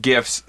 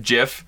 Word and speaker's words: GIF: And to avoid a correction from last week, I know that the creator of GIFs said that it's pronounced GIF, but he's an idiot. Just because you created something doesn't mean you write the rules. It's GIF because the first word is graphic, and it's not draphic, you stupid idiot.